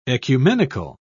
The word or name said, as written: ecumenical